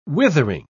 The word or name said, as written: withering